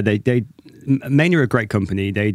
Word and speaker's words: Maine: Maine.